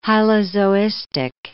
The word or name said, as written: hylozoistic